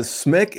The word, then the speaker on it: Smick: Smick, S-M-I-C-K. – Just like it sounds. Like it's gonna say, it's simple, man. It's simple. – It's just going to say Smick. It rhymes with stick.